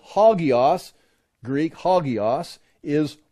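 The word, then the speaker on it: hagios (ἅγιος): Here's how you pronounce it, hagios.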